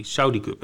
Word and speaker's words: Saudi Cup: Ja, Saudi, Saudi Cup. Hè. Wordt op zijn Engels uitgesproken.